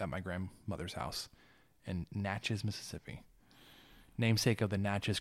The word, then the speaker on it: Natchez: Natchez. – It was like, dude, it's like matches with an M, but like it's an N.